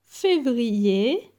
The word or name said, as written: Fevrier